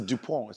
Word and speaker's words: DuPont: It's a DuPont. It's a silent T, by the way.